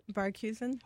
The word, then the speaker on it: Barghusen: Barghusen.